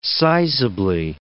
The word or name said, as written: sizably